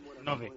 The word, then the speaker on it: nove: Quiero agradecer a los torcedores dos Santos por esos nueve, ¿cómo se dice nueve? Nueve. – Nove. – Nove años. – Nove años, que son 9 años.